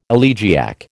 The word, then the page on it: elegiac: American English